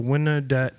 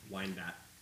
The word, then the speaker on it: Wyandotte: Between 1912 and 1913, the Gianola brothers took over the Wynnedette area rackets gang, starting a feud with Wynnedette mafia boss Vito Adamo. – Wyandotte.